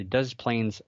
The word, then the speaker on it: Des Plaines: The driver witnessed the creature as he exited I-55 and saw the creature over the Des Plaines River. Yeah, that's how you say it.